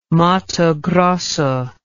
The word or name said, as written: Mato Grosso